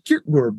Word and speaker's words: Kierkegaard: I've always said Kierkegaard, but that is incorrect. – Yeah, the Danish, the D after the R, you know, it's actually should be something more like Kierkegaard, but I'm not a fluent Danish speaker, so.